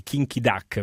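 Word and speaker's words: Kim Ki-duk: Sì, però bisogna riconoscere oggettivamente che Kinky Duck, per questa è la pronuncia,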